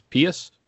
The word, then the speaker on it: Pius: I previously on episodes here called him Pius, but John Weideman, when they were calling the scrimmage on Sunday night, said it was Pius. So Pius Suter, I believe that's how it's pronounced.